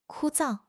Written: 枯燥

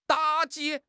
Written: 打击

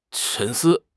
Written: 沉思

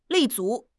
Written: 立足

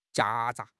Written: 夹杂

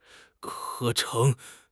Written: 合成

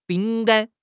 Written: bing3 gan1

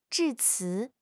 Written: 致辞

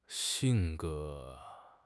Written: xing4 ge2

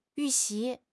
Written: yu4 xi2

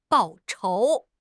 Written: 报仇

